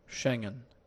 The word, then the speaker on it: Schengen: Pónmelo ahí, ¿cómo se dice de verdad esta palabra? – Schengen. Schengen. Schengen.